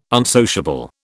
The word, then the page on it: unsociable: British English